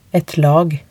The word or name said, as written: et lag